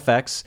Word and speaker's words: AFX: And is that how you pronounce it? I know it's kind of the yes. alias of Aphex Twin. Okay, so yeah. AFX,